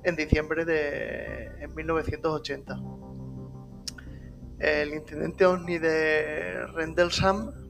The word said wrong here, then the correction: Rendlesham: Forest, a ver si lo digo bien, Rendel, Rendlesham Forest.